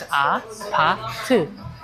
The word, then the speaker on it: APT: It looks like you need to pronounce ew, ew. No. You should pronounce it like uh, uh. So it's a pa tu.